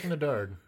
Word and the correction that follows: Eddard: How do you say his name? Ned? – Bring back Ned. – Eddard.